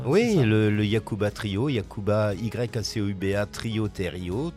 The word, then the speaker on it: Yacouba: Ah bah c'est parfait, vous linkerez vers Kitchenette, Yacouba comme ça se prononce, Y-A-C-O-U-B-A.